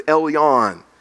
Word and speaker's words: Elyon: Among the Jewish people, the highest, that word highest in the Hebrew language is a word that we would pronounce as Elyon.